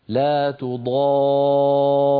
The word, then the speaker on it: لا تضار: لأن بعد الألف حرفان راء مشددة، يعني راءان، لا تضار والدة، فننطق رأين هذا في الوصل، لكن لو وقفنا عليها ماذا نقول؟ هكذا لا تضار، لابد من أن ننزل من الألف إلى الراء بضغط وبقوة إشعارا للسامع أن هذه الراء التي وقفت عليها راء واحدة هي في الوسط راء.